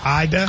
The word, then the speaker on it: Ada: I think it's Ada. – I don't believe that. You're telling me they pronounce ADA, Ida? – I'm almost certain. It's Ida, Oklahoma.